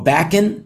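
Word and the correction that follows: Bakish: Backish, Bob Backish, that's his name.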